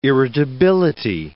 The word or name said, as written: irritability